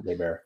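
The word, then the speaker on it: Naberrie: So Padme Nabeary, right? Is that Padme Nabeary Amidala. – Nabeary, right. – I think that's how you pronounce her name, Nabeary.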